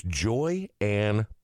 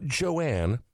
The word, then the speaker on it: Joy Ann: It's the Show, and our guest on today's episode, episode number 16, is Joy Ann. Not Joanne, please don't do that. J-O-Y-A-N-N.